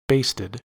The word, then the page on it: basted: American English